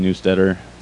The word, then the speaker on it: Neustetter: It's just Jimmy Neustetter, spelled like newsletter, but with a T.